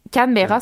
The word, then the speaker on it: Canberra: Euh, Canberra, ça se prononce comme ça.